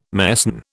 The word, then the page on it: mesne: British English